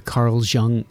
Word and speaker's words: Jung: Well, how do you feel about, you know, the collective unconscious, the Carl Jung, is that how you say it? – Mm-hmm.